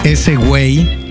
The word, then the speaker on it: esewey: Aquí detrás de los micrófonos y los controles, su servilleta, Alex Marín y Cal, mejor conocido como ese güey en todas las redes, E-S-E-W-E-Y, así se escribe, ese güey. Desde hace, híjole, yo creo que ya voy para 20 años con ese username de ese güey.